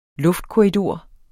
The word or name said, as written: luftkorridor